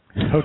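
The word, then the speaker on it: Hochevar: I think I finally came up with a phonetic that will work. Hoechay-ver. – Hochaver.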